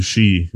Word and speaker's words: sidhe: The she is what they're called in Irish. There's a lesson for you. Guess how it's spelled. – Oh god, I know how it's spelled, but I don't remember. And it's funky as hell. – It is S-I-D-H-E. She.